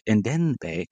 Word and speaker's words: Ndende: Ndende. God, I can never say his name right. And he's running into the box. There's no M in Ndende.